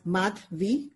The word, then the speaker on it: Mathvi: If you like the subject math, take the subject math and the letter V and just put it together and pronounce it as Mathvi together. And that's my name.